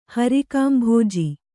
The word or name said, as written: ಹರಿಕಾಂಭೋಜಿ